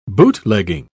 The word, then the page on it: bootlegging: British English